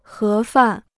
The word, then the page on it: 盒饭: hé fàn